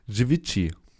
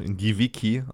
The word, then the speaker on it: Givici: Givici. Auf Deutsch Giviki, also G-I-V-I-C-I.